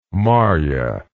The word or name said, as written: Marya